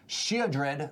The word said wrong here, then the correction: Sheoldred: the Whispering One. – Sheoldred. – No! 7 mana, 6. – There's an L. – It's a silent L, okay?